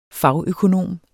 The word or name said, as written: fagøkonom